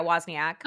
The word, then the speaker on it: Wozniak: He met up with his longtime friend, Stephen Wozniak. Am I saying that right? – I think you are saying it right. Yeah, Wozniak.